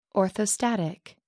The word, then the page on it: orthostatic: American English